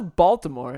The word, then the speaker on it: Baltimore: So the people of Baltimore say it Balmer. – That's just wrong. – Is it though? Because the locals say Balmer. – Yeah, that's just a butchering of how it's still Baltimore. It's Lord Baltimore. They just got lazy.